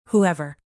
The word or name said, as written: whoever